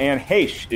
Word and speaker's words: Heche: And while it is true that Anne Heche is the correct pronunciation of her name, Anne Heche it may be Samm's opinion differing from our own, is a common mispronunciation of the name.